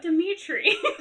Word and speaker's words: Dimitri: And she's like, oh, what do you think about Dimitri? And I was like, oh, gosh. – Oh. that's how that's pronounced.